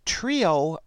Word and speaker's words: Treo: But in this article, Latif continued by saying, more recently, we have designed a Gillette Trio razor, it's spelled T-R-E-O,